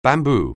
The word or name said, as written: bamboo